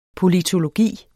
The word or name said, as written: politologi